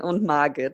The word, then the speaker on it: Margit: Who are Frederik and Margit? Am I saying that right? – Yeah, in German it's Frederik and Margit. – Okay. – But you were doing well, yeah.